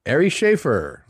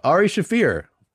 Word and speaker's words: Shaffir: Ari Shaffir. – It's pronouncing that a little incorrectly. – Oh, Ari Shaffir.